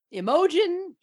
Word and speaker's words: Imogen: Because I was like, yeah, accent on the M, and she was like, wait, so it's Imogen? Like, and I, you know, you literally asked the wrong person, but then she was like, Imogen.